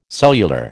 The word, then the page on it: cellular: American English